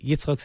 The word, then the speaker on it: Yitzchok: LinkedIn, Instagram, Facebook, Yitzhak Saftless, YITZCHOK SAFTLAS. Probably any way you spell it will be fine. I'm the only Yitzhak Saftless in America, as far as I know.